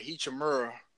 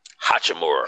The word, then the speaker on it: Hachimura: Hachimura, Hachimura. – Hachimura.